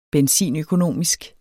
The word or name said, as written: benzinøkonomisk